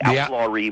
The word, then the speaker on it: outlawry: They called themselves the Outlawrists. Am I saying that right? I only ever read it in the book. – Yes, the outlawry movement, the movement to outlaw war.